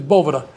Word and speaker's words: Boveda: And he looked at it and says, that's not how you pronounce it. This is a Spanish word. It's, it's Boveda.